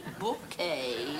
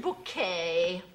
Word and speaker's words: Bouquet: It's bouquet, B-U-C-K-E-T, bouquet. The accent on the second syllable. – Ah, Mrs. Bucket. – Mr. and Mrs. Richard Bouquet, we have a reservation.